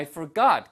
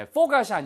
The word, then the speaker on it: forgot: I can't believe I forgot. 그죠? 네, I can't believe I forgot. 발음 주의하세요. 네, forgot이 아니고요. Forgot 하고 뒤에 액센트가 있습니다.